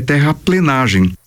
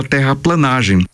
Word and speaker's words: terraplenagem: Outra é terraplenagem, que é pronunciada terraplanagem e escrita dessa mesma maneira.